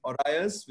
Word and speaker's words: Orios: I just learned to pronounce his, uh, his company's name, Orios, which is after a Greek word.